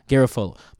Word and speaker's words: Garafolo: Uh, how do you say Mike? last name at NFL Network. What's his? – Garafolo. – Garafolo. Mike Garafolo tweeted that out, and people hit him up like, "Guys, Mike, you're, you're you're tripping.